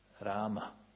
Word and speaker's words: Rama: A u sanskritu je A. A. Rama. Rama. Rama. A. A. Malo je kraći. Malo je kraći A nego naše.